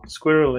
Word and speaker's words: SQRL: Yes, so, Squirrel, it's pronounced, which I like.